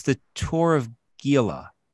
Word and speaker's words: Gila: Jason, it's an H, I think. Gila monster. – Yeah, I say Gila, like with like an H.